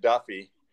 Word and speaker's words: Daffue: His name they keep pronouncing it Duffy, though it's spelled D-A-F-F-U-E. – That's exactly how it's supposed to be pronounced.